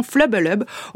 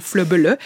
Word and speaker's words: FLBLB: Cette maison d'édition indépendante basée à Poitiers, qui publie des bandes dessinées et des romans photos, et dont le nom s'écrit FLBLB et se prononce donc Flubbelub ou Flubbele. C'est en gros le bruit que je vous ferais, Marie, si je vous faisais une grimace en vous tirant la langue.